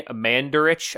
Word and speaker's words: Mandarich: We're going with Tony Mandarich, I believe is how you pronounce it. – Yeah, yeah.